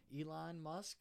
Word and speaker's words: Elon Musk: Elon Musk, is that correct? – I think you nailed it.